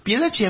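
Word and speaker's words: Piedade: Piedachi a mí me parece como una cargada de piedad, pero se dice así. – Sí, sí, Piedachi. Se escribe Piedad, ¿eh? Y se dice Piedachi.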